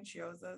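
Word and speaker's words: Chiozza: Is that how I pronounce his name? Chioza, is that correct? – Yeah, I think so.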